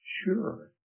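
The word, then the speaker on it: sure (צור): It's spelled T-S-U-R, sure is the word.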